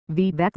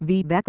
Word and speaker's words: Vivek: Yet Vivek Ramaswamy does not shy away from his Indian heritage. It is present in his name. His first name rhymes with cake, he explains, and his Hindu faith.